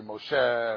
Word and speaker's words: Moshe: In Hebrew, certain words in Hebrew, many Hebrew words, the accent is on the second syllable. But sometimes, you know, even though we don't pronounce it that way, but it's really Moshe, and these, you know, as opposed to Moshe.